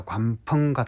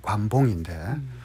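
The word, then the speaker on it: 관펑 (关锋): 그중에 이제 이를테면 저 중국의 학자 관펑 같은 학자들은 뭐 우리 발음으로 관봉인데 장자를 그야말로 이 무기력한 지식인의 전형이다.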